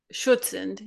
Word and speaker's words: Schutzhund: Shotzu training. – Schutzhund, yeah.